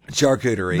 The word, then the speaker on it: charcuterie: Even though it's spelt with a C, you pronounce it with an S. Charcuterie.